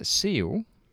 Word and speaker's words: Ciel: And then Seal, not spelled, this is spelled C-I-E-L. I'm saying Seal, it could be...